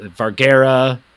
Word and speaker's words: Vergara: Nah, man, he gives nothing to her, and she is like, speaking of being way too supportive, Sofia Vergara, who I did Google her name 3 different times today to make sure I was saying it right, because she's got a difficult... Vergara. That's how you say it.